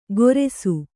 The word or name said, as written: ಗೊರೆಸು